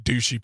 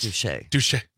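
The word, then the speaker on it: Douchy: He was also buried in the family home in, sorry, but it just looks, I know this is very American, I know it's probably pronounced Douche, but it looks like Douchey Paris, but it's south of Paris. – I think it's Douche.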